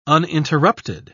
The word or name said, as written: uninterrupted